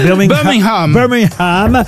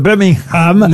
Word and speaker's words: Birmingham: On ne doit pas prononcer le G, hein, il paraît. – Birmingham est effectivement...